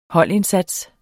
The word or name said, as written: holdindsats